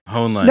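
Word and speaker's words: Hoenlein: We've got Malcolm Henla. I don't know how to pronounce this fucking guy's name. – Honla. – No, you go through these assholes. You know all these motherfuckers.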